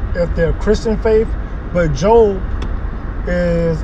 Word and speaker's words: Job: Now, this Sunday morning word, we're going to be talking about Job. It's spelled like job for those who are Bible thumpers, but don't really know the Bible like that.